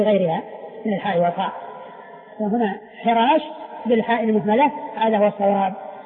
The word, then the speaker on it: حراش: من قال خراش يعني بالخاء بدل الحاء، في وارد ربعي، ربعي بن حراش هو بالحاء المهملة وليس بالخاء المعجمة، وليس بالخاء المعجمة.